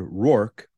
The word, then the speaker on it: Roark: You know, Jimmy John's, Jimmy John's is owned by a company called Rourke. That's spelled R-O-A-R-K.